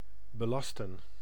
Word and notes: belasten: /bəˈlaːstə(n)/

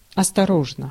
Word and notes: cautious: /ˈkɔːʃəs/

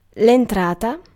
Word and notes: cue: /kjuː/

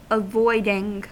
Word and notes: avoiding: American English

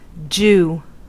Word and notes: jew: /dʒuː/

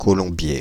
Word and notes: Colombiers: /kɔ.lɔ̃.bje/; Île-de-France

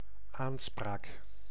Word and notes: aanspraak: /ˈaːnˌspraːk/

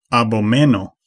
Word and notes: disgust: [dɪs.ˈgʌst]; /dɪs.ˈɡʌst/; /dɪsˈkʌst/

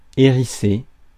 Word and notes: hérissé: France; /e.ʁi.se/

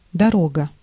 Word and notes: trip: [ˈtrɪp]; /tɹɪp/

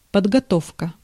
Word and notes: подготовка: /pədɡɐˈtofkə/; podgotovka

